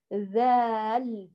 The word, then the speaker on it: ذال: انظري لحرف التالي وهو حرف ال، حرف الذال، اسمه ذال ليس زال ولا زه. البعض يقول حرف الزه، لا، لا يوجد حرف اسمه زال ولا زه، اسمه حرف الذال، اسمه ذال بهذا الشكل.